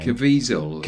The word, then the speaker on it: Caviezel: Caviezel. – Caviezel.